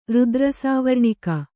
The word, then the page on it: ರುದ್ರಸಾವರ್ಣಿಕ: rudra sāvarṇika